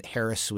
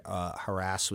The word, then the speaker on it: harass: At one point, Harris was the traditional pronunciation and widely favored. Harass was the outlier. By 1987, it was a 50-50 split. When we surveyed again in 2001, 70% preferred harass and 30% preferred Harris. And then in 2013, only 10% preferred Harris.